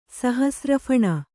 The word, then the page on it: ಸಹಸ್ರಫಣ: kannada